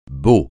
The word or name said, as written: beau